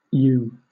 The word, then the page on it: ewe: British English